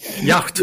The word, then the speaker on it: yacht: Yacht, vad säger man? Jakt. Ja. Eh, jakt.